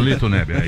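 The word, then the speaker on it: Litto Nebbia: Lito Nebbia. Fernandes pediu desculpas. Eu falei certo o nome dele? – É o Lito Nebbia.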